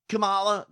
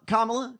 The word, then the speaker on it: Kamala: Listen, some of you don't like Kamala. Kamala? Kamala. – Kamala. – Kamala. – First on the first right.